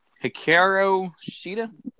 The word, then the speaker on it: Hikaru Shida: Hikaru Shida, is that right? – Yeah.